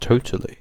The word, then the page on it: totally: British English